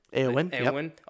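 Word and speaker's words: Éowyn: And likewise, Lady Eowyn, did I say that correctly? – Eowyn of Rohan.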